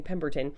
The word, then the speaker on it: Pemberton: Now, eventually, Dr. Pember, I keep mispronouncing Pemberton.